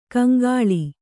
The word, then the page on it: ಕಂಗೊಳ್: Kannada